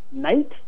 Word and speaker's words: knight: And you come across the word knight, K-N-I-G-H-T. – Mm-hmm. – Well, if you wanted to sound like Chaucer, you would say knit.